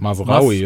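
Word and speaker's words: Mazraoui: Also Pavard gespielt, ich glaube Masurani, äh, wie, wie, wird, wie wird der ausgesprochen? – Masraoui, Mas oder? – Mas Masraoui? Ja, Masrani ist, glaube ich, der, der Typ von Jurassic World.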